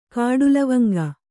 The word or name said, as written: ಕಾಡುಲವಂಗ